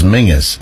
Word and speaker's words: Menzies: like Menzies, which of course we pronounce as Mingus, but apparently the Z is a substitution for a missing letter, a letter that wasn't Z. I can't remember what the letter was, but it was ng.